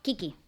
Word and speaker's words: Qiqi: Que hiciste un tratamiento que dijiste Okioki. – No, Kiki. – Kiki. – Kiki, se escribe Q-I-Q-I.